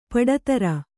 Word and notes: ಪಡತರ: Kannada

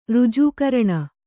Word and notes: ರಾಜಕಾರಣಿ: Kannada

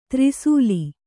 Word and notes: ತ್ರಿಸೂಲಿ: tri sūli; kannada

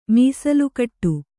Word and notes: ಮೀಸಲುಕಟ್ಟು: kannada; mīsalu kaṭṭu

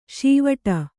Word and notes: ಷೀವಟ: Kannada; ṣīvaṭa

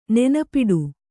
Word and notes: ನೆನಪಿಡು: nenapiḍu

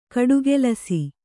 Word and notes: ಗಟ್ಟಿಕೆಲಸ: Kannada

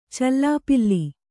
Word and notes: ಚಲ್ಲಪಿಲ್ಲಿ: calla pilli

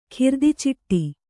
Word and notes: ಖಿರ್ದಿಚಿಟ್ಟಿ: khirdiciṭṭi

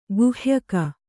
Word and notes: ಗುಹ್ಯಕ: guhyaka

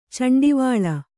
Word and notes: ಚಂಡಿವಾಳ: Kannada

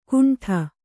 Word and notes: ಕುಂಠ: kuṇṭha

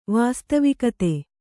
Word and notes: ವಾಸ್ತವಿಕತೆ: vāstavikate

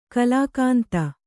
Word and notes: ಗುಲಕಂದ: gulakanda

